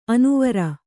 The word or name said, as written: ಅನುವರ